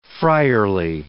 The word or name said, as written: friarly